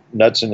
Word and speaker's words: Knudsen: Which, Knudsen in this case begins with a K, a silent K.